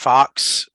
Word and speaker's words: Fuchs: it's Matt Fox, but it's spelled not exactly spelled Fox, not what the Fox say. – No, it's spelled F-U-C-H-S.